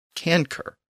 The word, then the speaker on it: cancer: Now, in Latin, the word was pronounced canker. Remember, the C was always pronounced as a K in Latin.